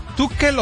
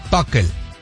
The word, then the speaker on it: Tuchel: ¿Es Tuchel o Tuchel? – Pues lo puedes pronunciar de las dos formas.